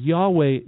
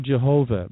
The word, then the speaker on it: Yahweh: And Yahweh is more of a correct pronunciation of the word Jehovah, who is God. We say Jehovah, but really it's spelled more like Yahweh in the Hebrew.